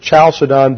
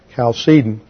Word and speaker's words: Chalcedon: Some people pronounce it Chalcedon because in the transliteration from the Greek, you have a key, what looks like the letter X in English, and that's usually transliterated as CH, but it was pronounced like a hard K in Greek. So the proper pronunciation is Chalcedon.